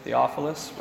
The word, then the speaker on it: Theophilus: And both Luke and Acts are addressed to Theophilus. That's how we were going to pronounce it, right, Suzanne? Theophilus? Right.